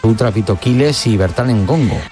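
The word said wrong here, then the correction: Ndongo: Dos veces me pasó y dos veces lo dije mal. Endongo, endongo, endongo. Le falta una E ahí a la hora de leer que me vendría fenomenal para la próxima.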